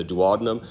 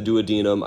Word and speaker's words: duodenum: You actually have three parts of your small intestine: the duodenum, some people pronounce it the duodenum, I say duodenum, and then the jejunum and the ileum.